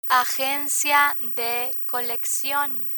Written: agencia de coleccion

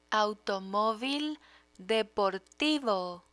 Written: automovil deportivo

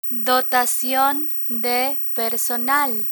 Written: dotacion de personal

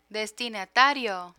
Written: destinatario

